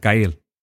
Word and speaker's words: Gael: Is it Gael? – Yeah, Gael. – Gael. Like Gael. – I don't think it's like Gael. It could be Gael, but I think it's Gael.